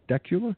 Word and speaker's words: Dacula: Or Dacula? How do you say that? Dacula. Okay.